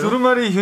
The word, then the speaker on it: 두루마리: 닉네임 집들이 하면 두루마지 휴 아, 두루마지 두루마지 뭐예요? 두루마리 휴지 사갈게요, 님.